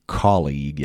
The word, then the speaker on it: colleague: اون یو و ای آخر تلفظ نمیشه، دقت کنید. و استرس هم همون اول کار، حرف سی یا صدای که، کالیگ، یعنی اونو باید محکمتر تلفظ کنید.